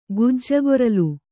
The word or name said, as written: ಗಿಜಗಾರ್ಲು